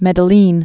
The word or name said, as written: Medellin